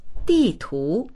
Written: di4tu2

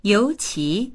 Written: you2qi2

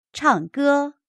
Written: chang4ge1

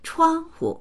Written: chuang1hu

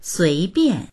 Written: sui2bian4

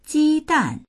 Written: ji1dan4

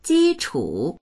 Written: ji1chu3

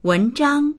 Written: wen2zhang1

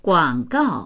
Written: guang3gao4

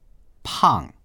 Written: pang4

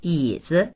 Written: yi3zi

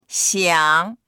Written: xiang3

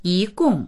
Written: yi2gong4

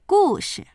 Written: gu4shi

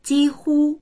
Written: ji1hu1

